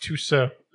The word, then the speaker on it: Tussaud: Tussaud. It's pronounced Tussaud.